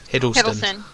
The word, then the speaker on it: Hiddleston: Hiddleston.